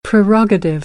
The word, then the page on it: prerogative: American English